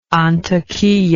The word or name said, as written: Antakiya